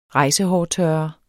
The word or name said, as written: rejsehårtørrer